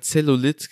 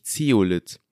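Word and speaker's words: Zeolith: Und zwar haben wir immer Zellulit gesagt, aber es heißt eigentlich Zeolit.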